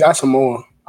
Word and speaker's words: Asamoah: So talk to me a little bit more about Brian Asamoah, I think is how you say it, Asamoah? – I think it's Asamoah. – Asamoah, oh, wow. Well, that was quite the bad pronunciation. My apologies.